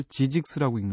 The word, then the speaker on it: Zzyzx: 그 고속도로를 타고 가다 보면 어, 캘리포니아에서 네바다 경계를 넘을 때쯤 나오는 큰 간판에 그 출구 간판이 나오는데 실, 아, 알파벳 스펠링이 이래요. ZZYZX. GZYGX예요. 이걸 어떻게 읽나 싶어서 굉장히 놀라서 나중에 제가 검색을 해봤었거든요. 근데 일부러 이게 이걸 지직스라고 읽는답니다.